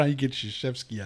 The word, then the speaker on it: Krzyzewski: I'm watching college basketball, and they were talking about Coach Krzyzewski. And they put his name up, and I'm like, that's Krzyzewski.